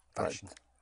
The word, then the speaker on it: waschen: Because the W is pronounced like a V, waschen.